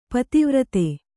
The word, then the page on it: ಪತಿವ್ರತೆ: pati vrate